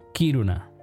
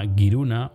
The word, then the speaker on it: Kiruna: La pronunciación varía, los suecos no samis lo pronuncian Kiruna, en tanto que los samis pronuncian algo parecido a Giruna o Girón.